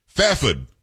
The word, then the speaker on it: Fairford: Fafford is what I was told how I should pronounce it from Kay.